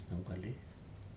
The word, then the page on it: numkali: Aneityum